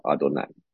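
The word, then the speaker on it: 阿 多 乃 (אדני): 这个我主希伯来文的读音就叫阿多乃，应该是啊，我现在手边没有阿多乃，对。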